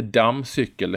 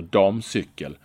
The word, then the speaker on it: damcykel: Ja, det heter inte dammcykel, det heter damcykel. Ibland är det ju skillnad på M om det är två eller ett.